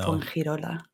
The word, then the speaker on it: Fuengirola: Fuengirola.